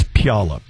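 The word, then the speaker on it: Puyallup: You know you're pronouncing that name incorrectly, right? – I knew you were going to put me on the air to pronounce that incorrectly. – I'm terrible. – I did my best. – I'm terrible. – I did my absolute best. – It's Pjolup.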